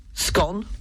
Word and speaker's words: scone: So scone is scone to rhyme with gone rather than scone to rhyme with bone. So scone and bone are a no-no, and scone and gone is the correct way.